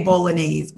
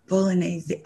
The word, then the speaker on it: Bolognese: I say Bolognese, but what is it? Bolognese or? – I guess. I always said Bolognese too. And then watching this episode, I'm like, why is Rinna saying it wrong? And then the internet's like, we've been saying it wrong. – Right. I was like, oh, Bolognese. Okay. So it has an extra syllable.